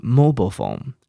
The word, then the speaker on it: mobile: 所以手机 mobile phone，这是美国人的念法哦，mobile phone，你可以念念看，那英国人是念成 mobile phone，mobile phone，OK。